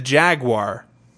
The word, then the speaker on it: Jaguar: Number four, the jaguar. preferably with the British pronunciation. Oh, Jaguar. Because it sounds so good.